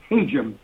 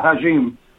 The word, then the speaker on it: Hajim: And, by the way, he said, Ed Hajim, not Hajim.